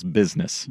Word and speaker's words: business: That's two syllables, business.